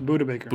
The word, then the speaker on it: Budda: Yeah, but speaking of making a dollar stretch, Cardinal safety Budda, is how you say it? Budda? – Budda Baker. – Budda. Okay, Budda Baker, which, I mean, quite frankly, sounds like an edible from a dispensary.